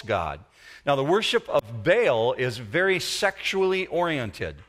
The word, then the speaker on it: Baal: And when you see the name Baal, B-A-A-L in the Bible, it's pronounced Baal in their language.